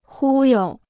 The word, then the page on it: 忽悠: hū you